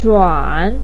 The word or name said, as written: zhuan3